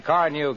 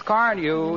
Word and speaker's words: Carnu: More car owners buy Johnson's Car New, you know, than any other car polish. It's spelled C-A-R-N-U.